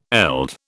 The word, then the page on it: eld: British English